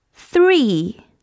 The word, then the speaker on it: three: two, t t t, two, two two two, 二 three, th th th, three, three three three, 三。说到 three 呀，大家一定要听好了 发音。 three 因为啊，three 这个是我们经常会发错的音，很多小朋友都会发成 three，不对不对，大家记住了，它是一个咬舌音，小朋友们用牙齿咬着舌头发出这个音，three，发对了吗？four，four。